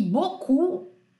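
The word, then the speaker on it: beau: é bizarro que boku se escreve e -A B E A U, quer dizer, o O é E A U e o U é O U.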